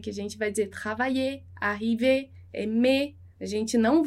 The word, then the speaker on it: travailler: Por exemplo, travailler. A gente viu os verbos arriver, aimer, então todo esse er no francês, ali para os verbos, ele é pronunciado e, né? Travailler, arriver, aimer.